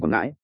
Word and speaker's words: Quảng Ngãi: Cơ, Quảng Ngãi gốc là phụ tư nghĩa của Quảng Nam, sau cách ra gọi là Quảng Nghĩa mà nhân dân quen đọc thành Quảng Ngãi, có khi là Quảng Nghệ ải.